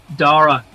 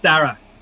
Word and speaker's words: Darragh: Most Americans, I have yet to meet an American who doesn't pronounce it Darragh, because the way you pronounce your ARs in the States is different to how we do it. Anyway, it's pronounced Darragh.